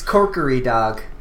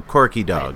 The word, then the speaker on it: Korkidog: let me make sure I pronounce his name correctly so I don't sound like Eugene. This one comes from Corky Dog. – It's Corkery Dog. – Kokiri Dog. – Yeah. – It is spelled K-O-R-K-I-D-O-G. So Corky Dog.